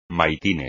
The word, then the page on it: maitines: Spanish